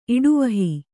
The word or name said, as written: ಇಡುವಹಿ